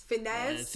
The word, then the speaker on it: Fiennes: Fiennes.